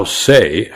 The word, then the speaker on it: Saie: How Say, and that is spelled S-A-I-E, became the only Knicks sponsor advertising to women.